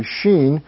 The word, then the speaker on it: Sin: I will pour my fury upon Sheen, as it is literally pronounced. You might have sin in your text, but it's actually Sheen.